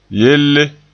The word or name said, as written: yelle